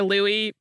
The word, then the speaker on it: Louis: Like, if somebody had French ancestry or if we were, like, saying an anglicized version of a French name, I would, L-O-U-I-S would be Louis. I never realized that a lot of people in the UK have the name spelled L-O-U-I-S pronounced as though it is spelled L-O-U-I-E.